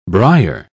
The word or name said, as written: briar